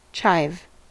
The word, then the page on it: chive: American English